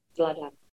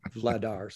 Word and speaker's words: Vladar: Vladar.